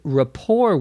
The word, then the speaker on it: rapport: So I say that having companionship and camaraderie, especially with people I had a good rapport with, was a welcomed change, a good change. Rapport, R-A-P-P-O-R-T, notice the T is not pronounced, means a good understanding, friendliness between and among people.